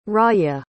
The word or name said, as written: rawya